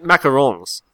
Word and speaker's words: macarons: I think they meant macarons. Like, because it's only one O. Like, it's a French pronunciation, so it's all nasal.